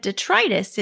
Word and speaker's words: detritus: I looked it up and it said detritus. It said it? With a long I.